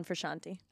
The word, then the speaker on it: Frusciante: John Frusciante was the guitarist that Roxie was referencing when talking about the Red Hot. It's Frusciante? – It's Frusciante. – Like bruschetta? – It's John Frusciante.